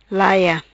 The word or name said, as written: Laia